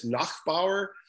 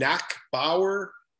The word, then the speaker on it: Nachbaur: So it started off in junior where my head coach, Dave King, when he got mad at me, the real pronunciation of my name is in Austrian is Nachbaur. It became Nachbaur in Canada, and that's what everybody called me, and it became Nachbar.